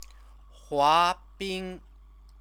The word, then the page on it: huabing: chinese